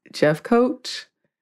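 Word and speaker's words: Jeffcoat: Yes, you can find me online on the internets, on Instagram at Not Apologizing, and on Twitter at Eves Jeff Coach.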